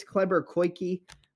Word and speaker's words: Kleber Koike: I think it's Kleber Koike. That's usually how they say his name.